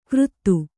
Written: ಕರ್ತು

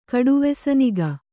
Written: ಕಡುವೆಸನಿಗ

